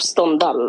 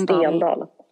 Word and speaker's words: Stendhal: Jag, jag bara menar att det inte uttalas Stendal.